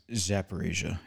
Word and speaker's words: Zaporizhzhia: Just real quickly, NPR is reporting that they shut down in the last hour, the I'm not going to pronounce this, Zaporizhzhia, their nuclear power plant. – Zaporizhia. – Okay, Zaporizhia power plant, thank you, has been shut down by the Russians.